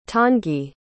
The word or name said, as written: tanguy